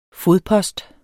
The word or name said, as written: fodpost